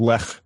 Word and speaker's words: Lech: Well, we should start doing my last name property, which is Lech. – Is it? – Yeah, that's my grandpa's like the only one who pronounces it right.